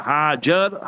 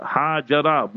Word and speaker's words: Hajar: That his first wife was Sayyida Sara radiallahu ta'ala anha, and then his second wife was Sayyida Hajar, Hajara, both ways you pronounce it.